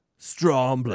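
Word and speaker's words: Strömblad: Is that how you say it? Stromblad. – That sounds good.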